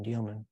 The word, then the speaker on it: Dielman: Gene Dielman, and I mispronounce it, I call it Gene Deal, it's Gene Dielman.